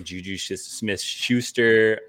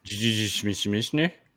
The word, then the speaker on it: JuJu Smith-Schuster: Can you say that again in case people didn't hear you? How did you say that exactly? – Amon-Ra St. Brown? Or no, Juju. You said it funny. That's all. – Juju Smith-Schuster? – Yeah, that's right.